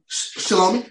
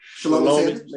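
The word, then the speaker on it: Shelomi: Is that Shalomi? Yes. – Mm-hmm. Shalomi. Man, I wanted to make sure I pronounce it.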